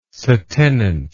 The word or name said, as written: setenant